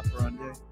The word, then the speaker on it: modus operandi: Modus operandi. – Modus operandi.